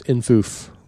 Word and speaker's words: infoof: Well, they whimsically insist that it be pronounced infoof.